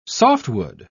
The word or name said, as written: softwood